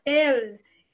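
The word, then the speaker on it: Ehle: É Jennifer L, gente? Oba, que oba, pronuncia? Oba. – Ele.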